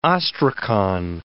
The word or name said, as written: ostracon